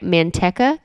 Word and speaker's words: Manteca: We started off the day, or the week, rather, with Garrett in his hometown of, am I saying this right, Manteca, California? – Yeah, sure.